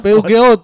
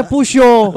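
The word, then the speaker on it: Peugeot: Ya, PUGOT, PUGOT. – Itu juga dibacanya pusho. Oh, pusho.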